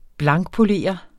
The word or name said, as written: blankpolere